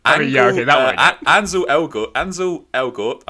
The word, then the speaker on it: Ansel Elgort: Ansel Elgort.